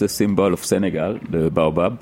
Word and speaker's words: baobab: Baobab, bui. We call it bui. Bui, yeah. Baobab, yes.